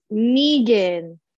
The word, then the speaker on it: Knigin: It is K-N-I-G-N. How do you say your last name? – K-N-I-G-I-N. – G-I-N, yes. – It's Negan. – Negan. – Me. No, you for some reason you said it right now, but you didn't. – Okay. – Like, it's Negan, like from Walking Dead?